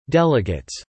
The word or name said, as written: delegates